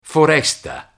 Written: foresta

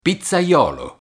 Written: pizzaiolo

